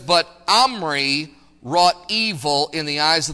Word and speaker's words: Omri: His father's name was Omri. It's spelled O-M-R-Y. It's pronounced Omri.